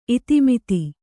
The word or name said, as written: ಇತಿಮಿತಿ